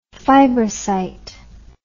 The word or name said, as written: fibrocyte